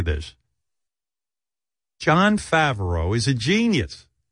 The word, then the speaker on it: Favreau: It's the actual pronunciation is Favreau, but the problem with John Favreau is he looks like Benjy, but he's a genius like Einstein.